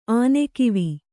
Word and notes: ಆನೆಕಿವಿ: ānekivi

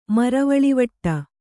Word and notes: ಮರವಳಿವಟ್ಟ: kannada

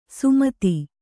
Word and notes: ಸುಮತಿ: sumati

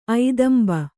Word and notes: ಐದಂಬ: Kannada

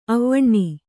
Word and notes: ಅವನ: avana

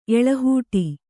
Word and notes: ಎಳಹೂಟಿ: eḷahūṭi

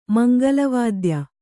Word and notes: ಮಂಗಲವಾದ್ಯ: mangala vādya

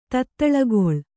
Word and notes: ತೊತ್ತಳಿಗೊಳ್: tottaḷigoḷ

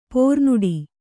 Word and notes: ಪ್ರೋನೋಟು: pronōṭu

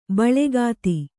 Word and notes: ಬೆಳುಗತ್ತೆ: beḷugatte

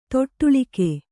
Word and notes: ತೊಟ್ಟಿಲಿಕ್ಕು: toṭṭilikku

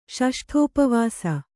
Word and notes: ಷಷ್ಠೋಪವಾಸಿ: ṣaṣṭhōpavāsi

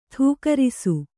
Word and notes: ಥೂಕರಿಸು: thūkarisu

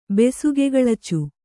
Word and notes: ಬೆಸುಗೆಗಳಚು: kannada

